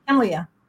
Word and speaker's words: Kemlia: Yes, Kemlia.